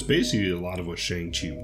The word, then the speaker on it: Shang-Chi: Shang-Chi, I think is how they pronounce it, actually.